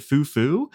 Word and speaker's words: Fuku Fuku: So I've got this one that it's spelled Fuku Fuku, okay. But I think you say it Foo Foo, and it's supposed to be like the sound of like soft things falling over or something like that.